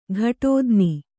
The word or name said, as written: ಘಟೋದ್ನಿ